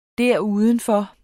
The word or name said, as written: derudenfor